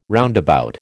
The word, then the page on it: roundabout: American English